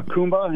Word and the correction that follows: Achumba: Am I saying that right? – Achumba. Uh, Golden Israel Achumba. – Achumba. Achumba. – Yeah, Achumba. Uh, linebacker at Penn State is going to come down and, and sing the national anthem for us.